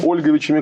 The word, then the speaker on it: Ольговичами: Это старшими Олеговичами, Ольговичами, как правильно говорить, потомками Святослава Ярославича, и младшими Мономашичами, потомками Владимира Мономаха.